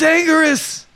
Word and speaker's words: Anguirus: I want to look up A-N-G-U-I-R-U-S. – Look out! It's Angerus. He's angry at us.